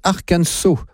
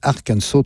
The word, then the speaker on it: Arkansas: Ça s'écrit Arkansas, mais ça se prononce Arkansas. Donc, les affaires sont claires. Je dirai Arkansas tout au long de cette émission.